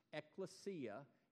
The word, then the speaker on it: ekklesia: The word that we translate church is a word that you pronounce ekklesia in the original language, in the Koine Greek, Ekklesia. Do you mind saying it with me?